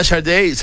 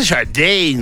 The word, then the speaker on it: Sade: Ahí está Sharday. Se dice Sharday, ¿no? – Sharday. – ¿Sí se pronuncia así? – Sí. Sharday.